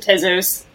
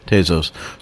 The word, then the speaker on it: Tezos: Yeah, I mean, it's a made-up word. So really, any way you pronounce it is quite fine. But my husband and I pronounce it Tezos. – Tezos.